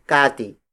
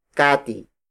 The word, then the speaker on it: kati: The next unit of weight is kati. Kati. Kati. Kati is spelled as K-A-T-I.